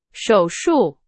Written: 手术

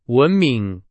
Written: 文明

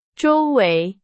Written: 周围

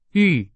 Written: 遇

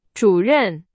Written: 主任

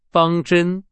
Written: 方针

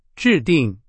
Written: 制定

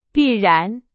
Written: 必然

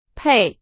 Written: 配

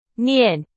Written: 念

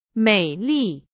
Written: 美丽

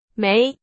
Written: 美

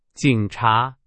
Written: 警察

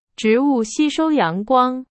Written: 吸收

